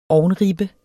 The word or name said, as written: ovnribbe